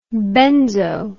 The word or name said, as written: benzo